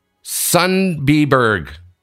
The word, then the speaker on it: Sundbyberg: There is an interesting event taking place in, I think I'm pronouncing this right, Warren, Sundbyberg Curling Club.